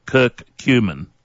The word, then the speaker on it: curcumin: I'll spell it for you. C-U-R, C-U-M-I-N, Kirk Cumin.